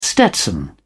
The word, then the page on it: stetson: British English